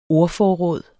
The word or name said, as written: ordforråd